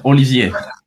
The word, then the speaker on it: Olivier: Yeah, Olivier.